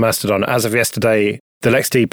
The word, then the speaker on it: LXD: Ubuntu Maker Canonical pulls in control of LexD. That's how you say that, isn't it? LXD it's spelled. – Yeah, it is. Yeah.